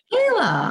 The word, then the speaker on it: Kahla: It's actually Kahla, but. – Kahla. Oh, I bet you get that a lot then. – Oh yeah. I mean, I wouldn't expect anyone to read it and think that the H is a Y.